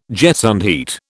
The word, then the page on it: gesundheit: British English